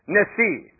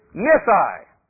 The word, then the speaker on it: Nissi: And so I'm going to use the pronunciation Nissi. The anglicized version of that word in my Bible is Nissi.